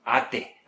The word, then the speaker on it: ate: Se escribe como... Ajá. Se pronuncia diferente. Yo ayer me comí un de guayaba. ¿Cómo se llama ese dulce típico de mexicano? – Ate. – Ate. Así se escribe. Y se pronuncia, si saben cómo se dice esa primera letra en inglés... Les va a venir.